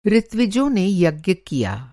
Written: ऋत्विज

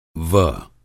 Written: व

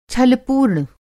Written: छल

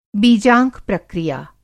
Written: बीजांक प्रक्रिया

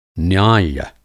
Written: न्याय्य

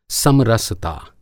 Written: समरसता

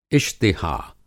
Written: इश्तिहा

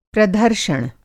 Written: प्रधर्षण

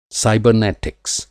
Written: साइबरनेटिक्स

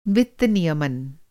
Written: नियमन

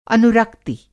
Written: अनुरक्ति

